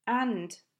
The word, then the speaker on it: and: Two things happen to and. The vowel becomes a schwa and the d sound disappears. Like I said earlier, we hardly ever pronounce the t or d sounds at the ends of words when we speak, even when we're speaking carefully. And now sounds like the article un.